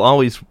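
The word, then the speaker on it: Alvvays: We had Always, which I will always pronounce as Always, because that's how it's spelled.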